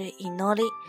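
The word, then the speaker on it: 祈り: 祈祷的意思，像咱们之前看的那个那个动漫叫《罪恶王冠》，它的女主女主角她的名字就是这个启，那么它的日文发音就是 inori，大家可以用这个来记忆一下 inori，哦 inori 就是祝愿。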